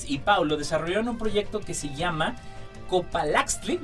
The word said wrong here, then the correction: Copalaxtli: ¿lo dije bien? Sí. Copalaxtli,